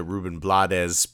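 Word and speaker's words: Rubén Blades: So, um, you know, we've got these three family units, and uh, Ruben Blades although my uh, my relatives always pronounced it Ruben Blades, but that's uh, the, the the Spanish. Yeah, Ruben Blades.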